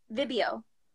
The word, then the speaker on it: Vibeo: The name of the app is Vibeo. – Vibeo. – So it's spelled like video with a B. – Mm-hmm. – And it's Vibe-O.